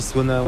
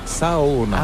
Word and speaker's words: Saona: Et comment on dit Sona? – En fait, on prononce chaque lettre, donc c'est Saona.